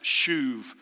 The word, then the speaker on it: shuv: It's called or pronounced shuv. It's transliterated S-H-U-V.